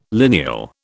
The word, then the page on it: lineal: British English